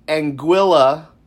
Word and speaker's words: Anguilla: Uba feels that they need some sort of group healing after the Anguilla, did I say it right this time? – You said it fantastic.